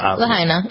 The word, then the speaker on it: Lahaina: Lahani? How do you Lahaina. Mm-hmm. – Lahaina.